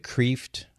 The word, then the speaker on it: Kreeft: As far as books are concerned, I'm a really big fan of Dr. Peter Kreeft, K-R-E-E-F-T. It looks like Kreeft, but it's pronounced Kreeft.